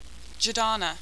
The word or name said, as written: jerdana